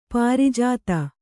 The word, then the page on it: ಪ್ರಜಾತೆ: Kannada